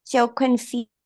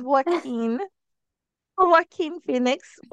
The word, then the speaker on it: Joaquin Phoenix: and it's starring Scarlett Johansson and Joaquin Phoenix. I hope I'm not butchering his name. – yes, you are. It's Joaquin Phoenix.